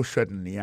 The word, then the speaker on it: certainly: en parlant de accent regardez bien pour un mot certainly accent tombé sur premier syllabe là yes certainly témoin point pour encore yes certainly comment accent tombé sur premier syllabe là.